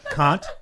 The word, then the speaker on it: Kant: Kant. That's how it's pronounced, really.